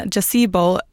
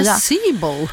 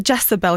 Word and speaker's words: Jezebel: Det är den amerikanska tidningen Gazebo publicerade en artikel om att de hade anlitat Etsy-häxor. Det är väldigt svårt ord att säga. – Jezebel? – Ja, eller Jezebel kanske uttalas.